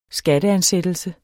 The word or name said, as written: skatteansættelse